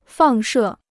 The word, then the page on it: 放射: fàng shè